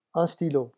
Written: un stylo

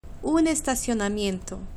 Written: un estacionamiento

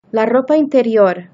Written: la ropa interior

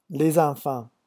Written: les enfants

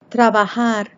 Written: trabajar

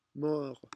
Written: mort

